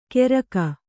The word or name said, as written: ಕೆರಗ